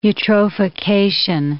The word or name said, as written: eutrophication